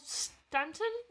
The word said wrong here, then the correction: Staunton: is that how you say it? – Staunton.